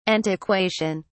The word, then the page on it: antiquation: British English